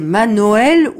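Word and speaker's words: Manoel: C'est Manoël ou Manuel? – Vous savez que Manuel vient de la... de la tradition juive. Mais au temps où je nais, Manuel s'écrivait avec O, pas avec U.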